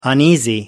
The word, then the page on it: uneasy: American English